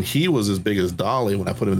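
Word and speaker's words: Dali: That's Dolly. That's D-A-L-I, Dolly. Salvador Dolly.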